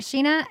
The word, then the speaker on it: Scheana: Mine is just at Sheena, S-C-H-E-A-N-A.